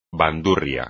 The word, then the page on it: bandurria: espagnol